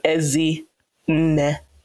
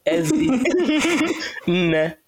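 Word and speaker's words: Ezinne: So Ezi, with each bits of my name, with my name, the inflections tend to go higher. So the way I see it is like a W that doesn't connect at the end. So that's where I see my name move through water. So Ezi Ne. Oh, it's two syllables. Sorry. Don't know why I thought three. Ezi Ne. So both of them just go up and then together.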